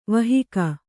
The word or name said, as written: ವಾಹಕ